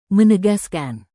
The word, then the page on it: menegaskan: Indonesian